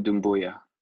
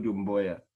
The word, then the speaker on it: Doumbouya: Going to butcher this. He's on the Detroit Pistons. Dumbuya? – Sekou Doumbouya. – Sekou Doumbouya.